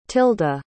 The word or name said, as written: tilda